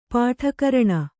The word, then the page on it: ಪಟುಕರಣ: kannada